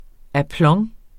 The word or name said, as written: aplomb